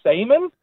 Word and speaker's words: Seaman: My dad, or obviously my name as well, our last name is Seaman. It's spelled S-E-A-M-A-N.